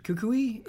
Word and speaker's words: Kukui: Something I want to say about Sun and Moon is Professor, I believe you pronounce it Kukui, is his name? – Yeah.